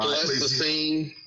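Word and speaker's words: Pleistocene: How do you say that saying? Plessany, right there. – Yeah. Right. Plessacene.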